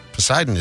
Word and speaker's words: Poseidon: A not silent P that I feel like should be silent. You don't say. – He would just be Poseidon? – Yeah. Instead of Poseidon or Sidon.